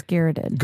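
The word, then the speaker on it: garrote: And then he gets garroted. – He did. What's garroted? – Isn't that what that is? – Garrot. It's garrot. – I'm sorry. Yeah. I totally have said that wrong like every time.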